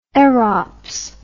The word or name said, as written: erops